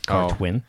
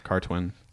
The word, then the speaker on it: Cartwyn: Yeah, do you want me to spell it? – Let's do it. – C-A-R-T-W-Y-N. Cartwin. – Oh, Cartwin. – And Zaintonge is X. – Oh, shit. – A-I-T-O-N-G-E.